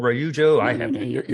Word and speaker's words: Ryujo: Let's shift back to Ryuo now, because there's a lot going on in the air at the same day. – Ryujo? – There's a Ryuo, Ryujo. – You're pronouncing it right.